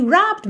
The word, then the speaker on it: robbed: O final dele é ed, mas o é não é pronunciado, certo? Então você não disse robbed e sim robbed. Robbed. Tá bem? Do B você já vai pro D.